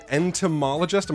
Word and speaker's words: entomologist: You are an entomologist. Am I saying it right? – Yes, you are.